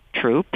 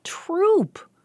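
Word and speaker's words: Throop: Hear that? Troop, which is how they say a bunch of stuff in Pasadena. – There's a Unitarian church that was named after him, and that's Troop. And a building originally on this campus was named after him, and it was Troop as well.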